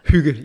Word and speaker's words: hygge: Have I said that right? Hygge, which is great. – Hygge, yes. – Hygge. – Yeah, yeah. – Hygge. You got close enough. – Hygge. – I've heard many variations.